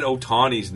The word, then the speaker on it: Otani: I just, the other thing with Dusty in this one, the way he said Otani's name, he could never, I don't think he said it right once. – Otani.